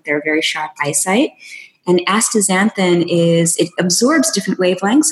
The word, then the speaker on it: astaxanthin: And then also astaxanthin. I think I'm saying it right. – Yeah, so astaxanthin.